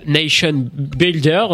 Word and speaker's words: Nation Builder: Alors, d'autres sociétés se sont mis dans le big data au service des candidats, comme Nation Builder. Je sais pas si je le dis bien, j'ai besoin de la confirmation. – C'était très bien, c'était parfait.